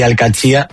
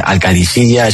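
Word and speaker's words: Al-Qadisiyah: Bueno, se suele decir Al-Katsia, pero también hay gente que dice Al-Kadisiya, es que es difícil, ¿eh? Ah, no es fácil, pero Al-Katsia es lo más fácil.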